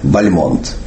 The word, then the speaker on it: Бальмонт: Фамилию поэта, конечно, нужно произносить с ударением на втором слоге – Бальмонт. Так он всегда произносил себя сам, так зафиксировано во многих воспоминаниях современников, так произносили его близкие, его дочь Нина Константиновна так произносила.